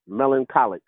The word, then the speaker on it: melancholic: Melancholic, like melancholy.